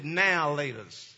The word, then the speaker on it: Now or Later: It's just like a candy that we had, or I had growing up, called Now or Laters. Now, we pronounced it Now Laters.